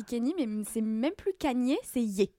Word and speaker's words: Kanye: c'est Kanye, c'est pas Kenny, pas Kanye. Kanye.